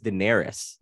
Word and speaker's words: Daenerys: Daenerys? – We're gonna count it because the pronunciation is so close. It's Daenerys.